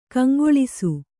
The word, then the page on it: ಕೆಂಗಲಿಸು: Kannada